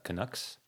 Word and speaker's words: Canucks: So yeah, this one is known as the Stanley Cup Riots, and it was the aftermath of the match between the Canucks.